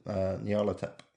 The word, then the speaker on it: Nyarlathotep: How do you pronounce that word? – I pronounce it Nyarlathotep.